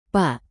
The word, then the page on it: pak: Indonesian